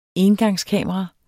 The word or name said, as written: engangskamera